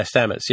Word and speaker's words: Stamets: I forget how that's pronounced. – Mm-hmm. Yeah, Stamets. Yeah, Lieutenant Stamets.